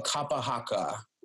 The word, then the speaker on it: Kapa Haka: It's called Kapa Haka.